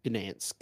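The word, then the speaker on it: Gdańsk: Gdansk.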